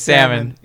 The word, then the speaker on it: salmon: Did you just pronounce the L in salmon? – Salmon? – Salmon? – Salmon? – Salmon? – Salmon. – I think it's salmon.